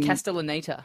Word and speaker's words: Castellaneta: How do you pronounce his name? Dan Castellan. – Castellaneta. – Castellaneta.